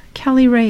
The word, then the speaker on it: Kelly Rae: I'm on Instagram at Ask Dr. Kelly Rae. Kelly Rae is spelled K E L L Y R A E.